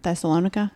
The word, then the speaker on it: Thessalonica: Thessalonica, is that how you say it? Mm-hmm. Yeah.